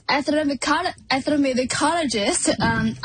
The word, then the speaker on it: ethnomusicologist: Remind folks what an ethnomusicologist is, if I said that right. – Yeah, you got it. As ethnomusicologist, I study music within cultures.